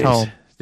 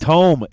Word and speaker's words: tome: Tome. – There you go. – Tome of Eyes? – Tome. It's tomb with is spelled with a B, and it's a place you bury people.